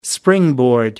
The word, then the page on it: springboard: American English